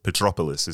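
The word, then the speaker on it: Petrópolis: So anyway, so your journey then begins in a place called Petropolis.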